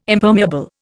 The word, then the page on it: Impermeable: Indian